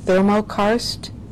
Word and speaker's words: thermokarst: I believe it's, I hope I pronounced this correctly, thermokarst? – That's correct, yes.